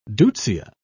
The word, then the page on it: deutzia: British English